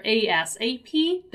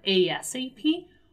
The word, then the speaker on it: ASAP: And also, I told you that the pronunciation of the expression ASAP, which stands for as soon as possible, can be read either ASAP, there is pronouncing every letter ASAP, or as ASAP, right? So ASAP or ASAP, both are okay, both are accepted, and both are used.